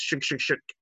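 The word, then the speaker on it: Chk Chk Chk: But we've got Alex G, we've got more Beck, and we've got Shook Shook Shook, or three exclamation marks. But apparently it's pronounced Shook Shook Shook.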